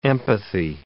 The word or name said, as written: empathy